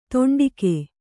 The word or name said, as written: ತುಂಡುಗೈ